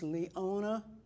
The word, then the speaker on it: Leona: My first name is Leona Ferguson. Leona Haslam Ferguson. First name, L-E-O-N-A. Haslam, H-A-S-L-O-M. Ferguson, F-E-R-G-U-S-O-N.